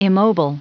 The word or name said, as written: immobile